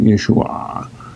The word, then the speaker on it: Yeshua: So that the noun, Yeshua, you can hear me saying it, Yeshua, okay, would have been pronounced without the guttural.